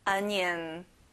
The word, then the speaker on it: onion: 앞에 O가 아니라 A예요. Onion. Perfect.